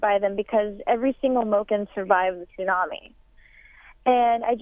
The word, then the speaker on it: Moken: Tell us the story of the time you spent with these people, the Moken. Am I saying that correctly? – That is correct.